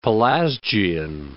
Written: Pelasgian

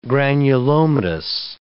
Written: granulomatous

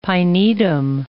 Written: pinetum

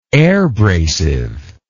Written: airbrasive

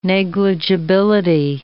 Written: negligibility

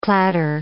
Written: clatter

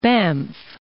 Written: Banff

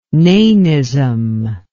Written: nanism

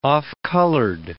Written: off-colored

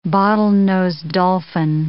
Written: bottle-nosed dolphin

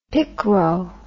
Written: picro

